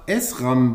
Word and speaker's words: SRAM: Du bist bei der Firma SRAM beschäftigt.